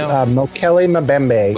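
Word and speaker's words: Mokele-mbembe: A few things in here we didn't even touch on is the Mokile Pimbe. How do you say that? – I think it's Mokele Mbembe.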